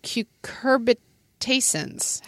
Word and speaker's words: cucurbitacins: So anyway, so in this family, which of course includes like zucchini, pumpkins, cucumbers, there are these toxins called cucurbitacins. How would you say that? – The way you said it. – Oh, really? Okay. – Yeah. And cucurbitacins.